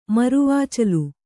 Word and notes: ಮರವಾಚಲು: maravācalu

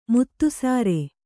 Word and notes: ಮುತ್ತುಸಾರೆ: muttusāre

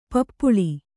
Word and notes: ಪಿಪ್ಪಾಲ: Kannada

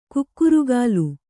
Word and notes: ಕುಕ್ಕರಗಾಲು: kukkaragālu

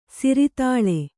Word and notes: ಸಿರತಾಳಿ: siratāḷi

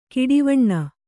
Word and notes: ಕಡುವೇನೆ: kannada

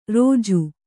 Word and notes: ರೊಜ್ಜು: rojju

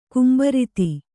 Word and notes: ಗುಂಬಾರತಿ: gumbārati

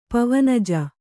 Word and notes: ಪವನಜ: pavanaja

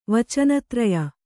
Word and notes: ವಚನತ್ರಯ: Kannada

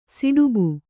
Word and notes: ಸುಡಾಬು: kannada; suḍābu

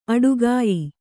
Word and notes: ಆಟಗಾಯಿ: āṭagāyi

